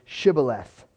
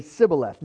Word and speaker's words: Shibboleth: The Ephraimites could say couldn't say Shibboleth. They could say Sibboleth.